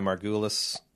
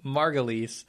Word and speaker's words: Margulies: For outstanding lead actress in a drama series, Juliana Margulis. How do you pronounce her name? Fuck you. – Margulies.